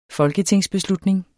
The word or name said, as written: folketingsbeslutning